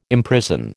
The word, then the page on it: imprison: American English